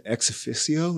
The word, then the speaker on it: ex officio: faculty senate president as ex officio.